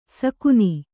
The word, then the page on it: ಸಗಾನ: sagāna